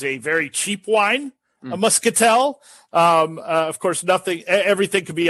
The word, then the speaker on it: Muscatel: And it's aged, I believe, in Muscatel. You'll have to correct my pronunciation, guy, if I butchered that one. – Pretty close. Cool. I think it's Muscatel. Yeah, Muscatel.